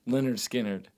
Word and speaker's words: Lynyrd Skynyrd: So my first one um, is Lynyrd Skynyrd's, pronounced Leonard Skinnerd.